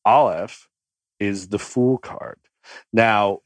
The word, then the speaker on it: Aleph: We say Aleph, and I know it's like speaking actual Hebrew, the way you said it. How did you say it? – The Aleph. – Yeah. So it's the same, different cultural, slightly different interpretations, but it's A-L-E-P-H in the English language is how you'd spell it.